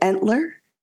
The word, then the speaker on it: Entler: Entler? – Yes. – Okay. Yep. Sorry, Christopher. Let's start again. – It's a brand new name. So I just got married. – Congratulations. – Thank you. – Terry Entler.